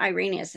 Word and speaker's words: Irenaeus: What I think it was Saint Irenaeus that says the joy of God is man fully alive. I think I completely butchered that. But if... – No, you got it.